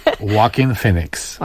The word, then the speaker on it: Joaquin Phoenix: Joaquin Phoenix.